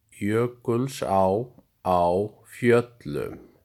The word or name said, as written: Jökulsá á Fjöllum